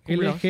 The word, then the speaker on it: LGBT: Y ellos se lo donaron a una casa hogar de la comunidad, que apoya a la comunidad LGBT... Siempre lo digo mal. – LGBT. Lo estabas diciendo bien, llegas bien a hacer.